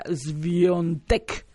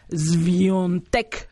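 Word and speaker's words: Świątek: Es que yo, es Iga Swiatek, pero me dice, se pronuncia Swiatek, así como con una S medio larga y la letra A es un sonido un. Swiatek, así es como se pronuncia.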